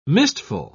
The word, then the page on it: mistful: British English